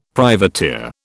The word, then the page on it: privateer: British English